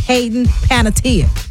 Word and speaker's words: Hayden Panettiere: It's Hayden Panettiere. That's how she says it.